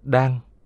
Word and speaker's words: đơn: Đơn hay tòa, sàn, ghế, giường, đọc đúng là đan, là chỗ ngồi của mỗi tăng nhân trong thiền đường.